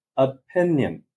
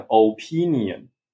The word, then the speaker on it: opinion: 他说这个字是 opinion，是三个音节，可是我讲 opinion。